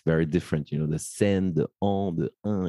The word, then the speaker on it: Seine: Is it Seine? – Yeah, yeah, yeah, yeah. The okay. Seine, the Seine, but it's okay. Seine.